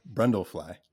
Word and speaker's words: Brundlefly: Bartlefly. – I also, Brundlefly. – Brundlefly.